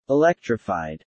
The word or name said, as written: electrified